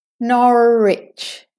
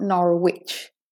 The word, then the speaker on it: Norwich: And a place in England, Norwich. Norwich, not Norwich.